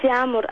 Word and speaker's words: Yağmur: Yağmur. – Yağmur. – Eigentlich Yağmur, aber das könnt ihr nicht aussprechen.